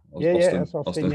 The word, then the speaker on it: Austen: Austen. Yeah. Cool. It's spelled E-N, though.